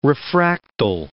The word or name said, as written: refractile